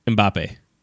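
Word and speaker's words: Mbappé: Mbappe.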